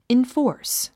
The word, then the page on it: enforce: infɔ́ːrs; インフォース